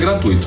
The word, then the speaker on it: gratuito: A palavra é gratuito, sem acento no I. Você pode olhar qualquer dicionário, não haverá acento no I. Por isso, a pronúncia é gratuito. Se houvesse um acento no I, que é o caso, por exemplo, de saída, né, da palavra saída, acento no I, aí sim seria gratuito.